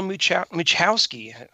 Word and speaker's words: Muchowski: Today's show, we feature Dr. Paul Muchowski.